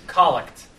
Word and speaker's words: collect: C-O-L-L-E-C-T, I thought. – Oh, the collect. – Collect. – Right. – C-O-L-L-E-C-T. – It's spelled collect. – Right. – Yeah. Pronounced collect.